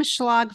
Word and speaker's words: Schlag: Yeah, you can find me everywhere under Kim Schlag Fitness. My last name is spelled S-C-H-L-A-G.